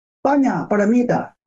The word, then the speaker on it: 般若波罗蜜多: 般若波罗蜜多就这么说，这就是古印度语言。般若波罗蜜多就是般若波罗蜜多。 – 哦，念因或者波罗蜜多。 – 其实应该念成般若波罗蜜多，应该这么念。